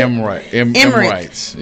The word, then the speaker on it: Emirates: Emirates.